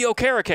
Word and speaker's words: Okereke: Bobby Okereke, and everyone's like, oh, it mispronounced his name. And it's like, no, that's correct.